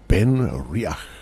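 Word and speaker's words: BenRiach: Ben Riach.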